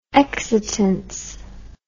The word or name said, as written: exitance